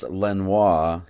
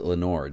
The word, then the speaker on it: Lenoir: Although normally it's pronounced Lenoir, he pronounced it Lenore.